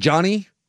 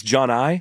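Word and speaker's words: Johni: Is it Johnny? – I think it's John I. – John I? – It looks like Johnny, but it's not pronounced Johnny.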